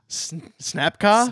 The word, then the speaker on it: Snapka: Snapka? – Snapka. – How do you spell that? – S-N-A-P-K-A.